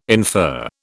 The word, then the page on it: infer: British English